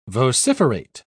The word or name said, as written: vociferate